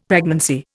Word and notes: pregnancy: Indian

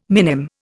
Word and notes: minim: Indian

